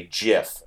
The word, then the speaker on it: GIF: But then they also say same reason GIF is a hard G, because G stands for graphics. Hard G, which is my reasoning.